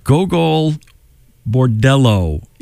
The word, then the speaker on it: Gogol Bordello: I can never pronounce this band's name. Gogol Bordello is what it is.